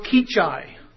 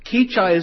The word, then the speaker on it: Kechi: And the town is Kechi. Now, that's a tough one. Kechi is spelled K-E-C-H-I. K-E-C-H-I, Kansas.